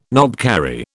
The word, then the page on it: knobkerrie: British English